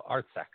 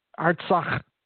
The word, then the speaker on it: Artsakh: No, Artsec. – Spell it. – Artsakh.